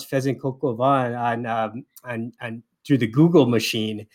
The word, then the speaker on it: coq au vin: I used to say coq au vin all the time. It's like coq au vin. I like to say vin because it's short for vino, but it's coq au vin.